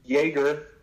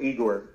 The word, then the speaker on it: Yegor: Yeah, so uh, he has two pronouns. Uh, he's Yegor, okay, or Y E G O R, or Igor, uh, E G O R.